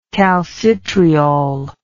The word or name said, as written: calcitriol